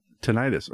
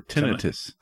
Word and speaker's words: tinnitus: He's got tinnitus or tinnitus. Some people pronounce it that way.